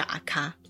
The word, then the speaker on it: 垢: 嗯，卡基就是钥匙，那阿卡呢是 account 的意思。嗯，只是比较特别的是，在日文呢，你会蛮常看到污垢的那个垢字，因为那个垢字的读音就叫阿卡，那它就是 account 的简称。